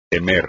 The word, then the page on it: temer: espagnol